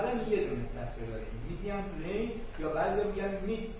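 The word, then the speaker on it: median: میدیان غلطه. میدیان نوشته میشه ولی میدیان تلفظش هست.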